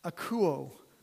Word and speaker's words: akouo: The word obey in the Greek comes from a Greek word, I wrote down how to pronounce it from Chris, akouo, which is where we get the word acoustic from.